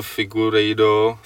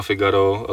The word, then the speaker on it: Figueiredo: Adam, líbilo se mu, jak vyslovuje Figueiredo, což se prostě mě blbě vyslovuje, říkám Figaro, ale je to Figueiredo.